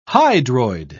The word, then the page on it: hydroid: British English